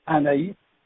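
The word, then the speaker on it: Anaïs: I'm thinking it's like Anais, but I don't know if I'm saying that correctly. – It's French, Anais.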